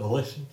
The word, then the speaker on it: Volition: Volition? Well, is that how you pronounce it? Volition? – Yeah.